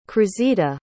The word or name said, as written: cruzita